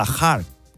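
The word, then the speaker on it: Kharg: que se pronuncia a lo chiquito de la calzada, Hark.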